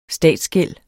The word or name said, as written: statsgæld